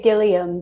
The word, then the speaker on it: Gilliam: You can say it however you want. I love your accent. I sometimes say Gilliam, but...